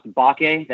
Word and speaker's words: Mbake: His last name is pronounced Bake. The M is silent.